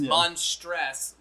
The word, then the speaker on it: Monstress: It's ours is Monstress, like actress monster.